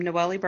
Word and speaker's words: Noellie: Um, Noellie Burger.